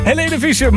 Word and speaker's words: Helene Fischer: ist das neue Single von Helene Fischer. Nou, ik probeer even wat. Ja, Helene. Is dit al fenomeen? Oh, oké. Okay. Ja, dankjewel. De juiste uitspraak dus. Helene Fischer, morgen komt ze met een nieuwe single.